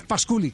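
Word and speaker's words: Pasculli: Ah, la pronunciación es Pasculi, claro,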